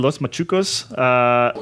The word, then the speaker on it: Los Machucos: and then Wednesday's Machuco. Am I saying that correctly? Los Machucos, which yes.